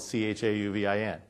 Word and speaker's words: Chauvin: I say like male chauvinist without the ist or the pig part. I am male, and my last name is Chauvin, and it's spelled Chauvin.